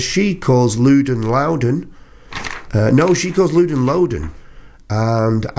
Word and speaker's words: Loudon: call me Loudon." Ooh, so there you go, from the horse's mouth. Loudon Wainwright III pronounced his name Loudon.